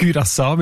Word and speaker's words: Curaçao: Jetzt hast du mir den Namen von der Insel gesagt, wie man den ausspricht. Curaçao mit Ü in dem Fall.